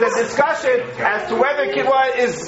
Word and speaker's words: quinoa: where there's a discussion about quinoa, or quinoa, how is it pronounced? – Quinoa.